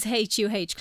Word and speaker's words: Her: Am I saying that right? – I usually say HUH now. – Okay. – And a lot of our customers do too, so fair.